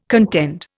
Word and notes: contend: Indian

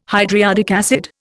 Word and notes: hydriodic acid: Indian

